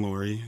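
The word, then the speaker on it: Loory: My name is Ben Lorry. It's spelled kind of funny. It's L-O-O-R-Y.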